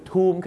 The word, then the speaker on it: Tomb: Tomb ครับเหมือนคางทูมนะฮะ